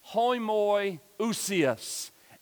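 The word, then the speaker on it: homoiousios (ὁμοιούσιος): So if you have the iota, it's pronounced homoiousios.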